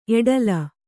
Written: ಎಡಲ

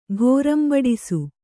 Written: ಘೋರಂಬಡಿಸು

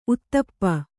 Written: ಊತಪ್ಪ

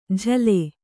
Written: ಝಲೆ

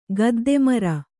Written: ಗದ್ದೆಮರ

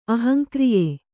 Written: ಅಹಂಕ್ರಿಯೆ

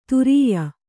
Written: ತುರ್ಯ

